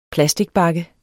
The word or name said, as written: plasticbakke